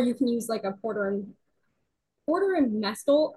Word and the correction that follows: mortar and pestle: I c can never pronounce the first. – Mortar and pestle.